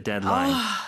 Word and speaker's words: deadline: 네, 근데 발음은 deadline. 뒤 발음이 거의 먹는 듯이 사라지죠. 그래서 데드라인이라고는 하지 않습니다.